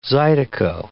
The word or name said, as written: zydeco